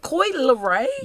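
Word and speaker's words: Coi Leray: Is it Koi Leray? – Yeah. – I have been saying her name wrong for like a year, you know. – Oh, geez. – This is the first time I got it right, which I feel terrible about.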